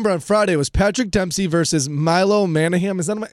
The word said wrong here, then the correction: Manheim: Am I saying his name correctly? – Manheim.